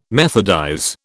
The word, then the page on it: methodize: British English